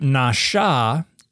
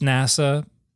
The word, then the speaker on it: nasha: There's a verbal root nasha, and because this is usually written with diacritics, and they don't know how to read diacritics, they think N-A-S with a chevron on top of it, A, is pronounced NASA, but it's nasha.